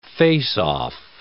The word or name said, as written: face-off